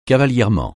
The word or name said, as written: cavalièrement